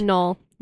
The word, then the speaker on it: Knoll: Knoll. – Okay, the K is silent.